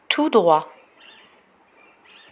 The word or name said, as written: tout droit